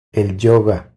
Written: El yoga

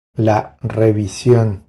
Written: La revision

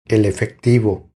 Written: El efectivo

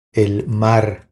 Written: El mar

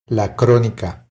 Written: La cronica

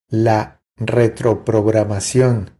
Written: La retropropagacion

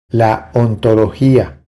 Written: La ontologia

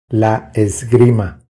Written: La esgrima

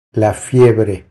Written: La fiebre

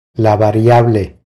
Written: La variable

